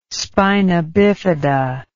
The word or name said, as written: spina bifida